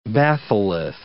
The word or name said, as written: batholith